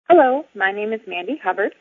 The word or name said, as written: Mandy Hubbard